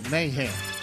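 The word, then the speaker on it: Mahan: No, I think it's Mayan. – It's Mac Magentag. – Mayhan. – How do you say your last name, Mike? – It's M-A-H-A-N. Mayhan.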